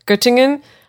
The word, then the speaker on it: Göttingen: How do you think I pronounce this? – Göttingen.